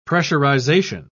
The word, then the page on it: pressurisation: British English